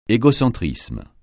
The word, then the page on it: égocentrisme: francais